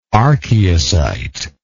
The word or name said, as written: archeocyte